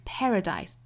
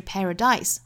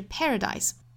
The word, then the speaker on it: paradise: I've been to paradise，注意它的重读音节在第一个音节哈，是 paradise，哒哒哒，不是 paradise，很多同学会读成后面这个音节重，不对的哈，应该是 paradise。